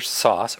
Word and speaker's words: Source: So your show, Tartar Sauce, or is it Source? – Well, that's okay. The question. My pronunciation was sauce, but it's spelled source.